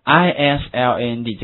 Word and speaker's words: island: island 这个 s 它是不发音的，所以读成这样的话，是对的。